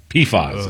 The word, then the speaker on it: PFAS: PFAS is how they pronounce it.